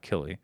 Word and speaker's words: Killy: Killey is the way it's spelled out, K-I-L-L-Y, Killey.